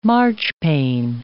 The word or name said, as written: marchpane